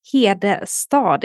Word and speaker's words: Hedestad: And my surname is Hedestad. It's a very Scandinavian name. So I understand that it's not 100% easy for you to pronounce it.